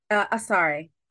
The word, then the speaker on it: Asare: Asare.